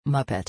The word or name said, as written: muppet